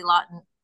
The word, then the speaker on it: Laughton: I have my website, which is my name, Julie at Julie Laughton.